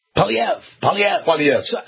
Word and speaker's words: Poilievre: Pierre Poilievre? Is that right? Poilievre? – Poilievre.